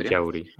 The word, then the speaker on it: Ellauri: E-L-L-A-U-R-I. Capaz que lo dije mal, lo deletreé, no sé. Pero yo hubiese dicho Ellauri siempre. – Ellauri, ok. – Pero acá dicen Ellauri.